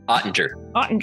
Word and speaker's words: Oettinger: Jake Oettinger. – Oettinger.